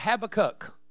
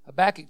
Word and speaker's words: Habakkuk: I used to call it Habakkuk. And actually in Hebrew, that's probably a more pronunciation.